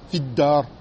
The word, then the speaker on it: في الدار: on ne la prononce pas en med. On la prononce comme une harakat, tout simple. et on enchaîne directement dans harf mushaddad. Nahu, مثلاً كلمة في الدار رجلون. Tu prononceras comment? في الدار. Comme s'il y avait في avec une kasra mashra accrochée au dal mushaddada.